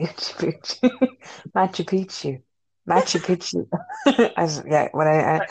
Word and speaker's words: Machu Picchu: Then yeah, I think I'd spend the rest of it on travel and go to like Machu Picchu.